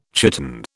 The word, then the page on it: chiton: British English